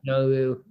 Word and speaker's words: Nauru: Nauru, N-A-U-R-U, is number one. – Nauru, yep. – How do you say it? – Nauru.